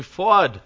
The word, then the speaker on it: Fuad: Call me Fuad. Fuad sounds like quad with an F.